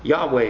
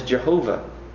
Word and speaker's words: Yahweh: Yahweh is how we pronounce it. The Latin is Jehovah.